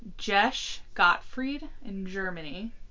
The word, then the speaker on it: Gesche: Yeah, her name's spelled GESCHE. And I literally searched for, like, 5-10 minutes on, like, how to pronounce this. And, like, went and, like, heard different pronunciations. I was like, this is the easiest one.